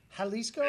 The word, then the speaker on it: Jalisco: So J is an H? Jalisco? – J, yeah.